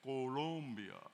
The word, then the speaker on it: Colombia: Porque yo puedo practicar la pronunciación de la vocal O. – Because I can pronounce the vowel O. – Pronunciación correcta es Colombia.